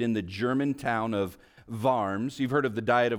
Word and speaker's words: Worms: It's spelled Worms in our English, but it's pronounced Worms.